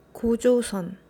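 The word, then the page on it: 고조선: Gojosun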